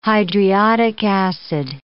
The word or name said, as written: hydriodic acid